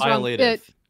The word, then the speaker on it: violative: At this time, site policy has not found explicitly, I don't even know how to pronounce this, violative? That sounds violative. – Wrong. – Violative.